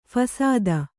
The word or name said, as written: ಫಸಾದ